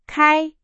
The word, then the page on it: 开: Chinese